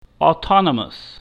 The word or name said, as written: autonomous